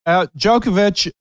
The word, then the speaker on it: Djokovic: And it wound up being Novak Djokovic. That's how you say it? – Yep. Djokovic, Croatian.